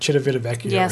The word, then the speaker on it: Civitavecchia: It's like Civitavecchia or something like that.